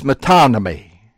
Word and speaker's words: metonymy: I don't know the actual pronunciation of this name for this figure of speech, but I'll tell you how we pronounce it in West Virginia. And it is metonymy.